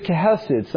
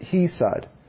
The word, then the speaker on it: chesed: And the word is chesed. Some pronounce it hesed.